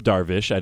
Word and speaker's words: Darvish: Well, in full transparency, I screwed up Darvish. I didn't get the H sound at the end of that one.